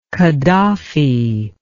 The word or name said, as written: Kaddafi